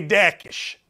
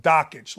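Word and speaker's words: Jokić: Now, you're going to say to me, because this is what gamblers do, you're going to say to me, hey, Dakish. It's pronounced Dockage, like Jockage, but with a D.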